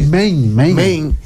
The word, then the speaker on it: Maine: Yo vi unos reportajes de Maine, que se pronuncia así. – Maine.